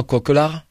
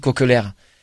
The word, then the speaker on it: Kokelaere: Et aujourd'hui, le coup de gueule de l'émission, c'est encore et toujours lui, François Coquelard, n'en déplaise à Jacari, qui pense qu'on dit Coquelaire. Ça s'écrit K-O-K-E-L-A-E-R-E.